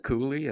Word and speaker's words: Cooley: I pronounce it Cooley, and I think that's Cooley, probably. Cooley, yeah.